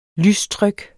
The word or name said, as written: lystryk